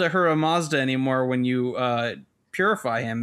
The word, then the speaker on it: Ahura Mazda: Okay, so anyway, so you find it, you get a little standard dungeon, you encounter the creator god of Zoroastrianism, Ahura Mazda, really? Like the car. – Ahura Mazda.